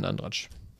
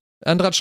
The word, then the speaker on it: Andratx: Andratsch schreibt man mit X, ne? Andrax. – Ja, aber ich glaube mit T davor. Also Andratx.